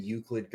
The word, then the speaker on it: Euclid: And to me, what it is that Rain and Euclid go, that's even how you pronounce it, go hand in hand. – Yeah, you said it right.